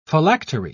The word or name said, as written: phylactery